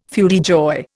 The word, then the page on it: feu de joie: Indian